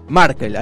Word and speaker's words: Merkel: Merkel, ahí está, Merkel, exactamente. – Merkel, sí.